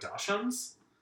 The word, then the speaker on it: dachshund: It seems like they're only good with dachshunds, but maybe. – Dachshund. – Is it a dachshund? – You say dachshund. – Dachshund? – Yeah. You don't say it the way it's spelled.